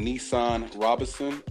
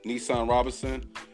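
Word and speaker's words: Nesahn Robinson: Once again, I'm Nissan Robinson, the real estate expert. I'm on YouTube with Nissan Robinson. It's spelled N-E-S-A-H-N Robinson, R-O-B-I-N-S-O-N, Nissan Robinson.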